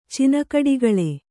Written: ಚಿನಕಡಿಗಳೆ

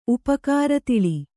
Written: ಉಪಕಾರತಿಳಿ

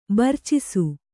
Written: ಬೆರ್ಚಿಸು

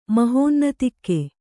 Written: ಮಹೋನ್ನತಿಕ್ಕೆ

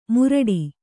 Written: ಮುರಡಿ

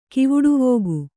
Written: ಕಿವುಡುವೋಗು

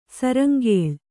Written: ಶೃಂಖಲ

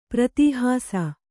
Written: ಪ್ರತೀಹಾಸ

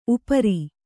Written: ಉಪರಿ

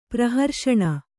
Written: ಪ್ರಹರ್ಷಣ

